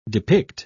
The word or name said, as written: depict